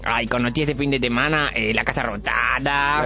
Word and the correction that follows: Casa Rosada: La Casa Rosada, pronuncie que dijo. Rotada no, Rosada.